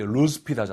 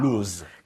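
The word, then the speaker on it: loose: 이거 우리 루스핏 하잖아요. – 맞아요. – 루즈. – 근데 루즈가 아니라 루스에 가깝게 루스. 발음하셔야 됩니다.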